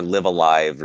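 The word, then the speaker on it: Live A Live: I thought it was going to be Live a Life or Live Alive or Live a Life. – All of those used to be the case. – Yeah, nobody knew how to say this. It's Live Alive. We've got an official pronunciation correct. Now. A lot of people thought it was Live a Evil because the second live is like reversed.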